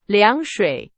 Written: 凉水

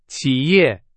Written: 企业

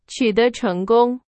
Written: 成功

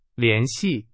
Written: 联系

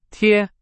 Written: 贴

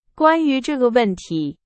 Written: 关于

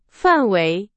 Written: 范围

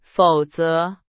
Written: 否则

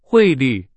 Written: 汇率

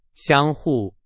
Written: 相互

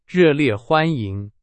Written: 热烈

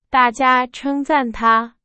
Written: 称赞

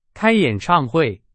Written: 演唱会

